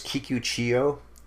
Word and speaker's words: Kikuchiyo: I'll say it wrong, but I'll say he's the imposter Kikuchiyu. – I think it's Kikuchiyo.